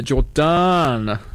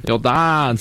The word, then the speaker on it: Jordaan: So the Palingaroa, or the eel riot, was a riot that broke out in the Jordaan, spelt with two A's. – Jordaan, suburb of Amsterdam.